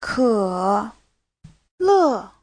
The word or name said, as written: ke3le4